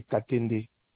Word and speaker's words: Katende: Mon nom, c'est Katende.